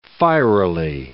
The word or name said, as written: fierily